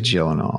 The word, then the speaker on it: Gyllenhaal: It really is Gyllenhaal.